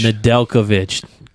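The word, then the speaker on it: Nedeljković: Nedeljkovic. – Nedeljkovic.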